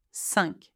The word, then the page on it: 5: French